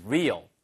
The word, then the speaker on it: real: How do you know if it is real? 자, 그래서 바로 R-E-A-L. 이거 알아두세요. 발음이 real 하면은 이거는 진짜의란 뜻이고요. 그 다음에 R-E-A-R. 후방의란 뜻으로 쓸 때는 rear. 걔는 rear, 얘는 real. 을 발음이 하는 거죠.